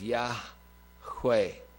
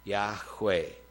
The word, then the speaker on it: Yahweh: And traditionally, that has been suggested to, that you could pronounce that as Yahweh. Yahweh, YHWH.